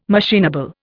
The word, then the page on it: machinable: Indian